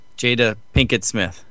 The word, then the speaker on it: Jada Pinkett Smith: Jada Pinkett Smith.